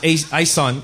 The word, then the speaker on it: Ayson: So when they came here from the Philippines, it was Ison, A-Y-S-O-N.